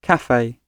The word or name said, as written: cafe